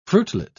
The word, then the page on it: fruitlet: British English